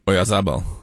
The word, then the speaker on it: Oyarzabal: Oyarzabal. – Oyarzabal.